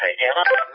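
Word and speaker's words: Hytera: Wie gesagt, das ist eben das Gerät von Motorola, geschrieben M-O-T-O-R-O-L-A. Und der andere Anbieter, hast du schon richtig gesagt, heißt Hightera.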